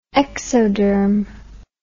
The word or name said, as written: exoderm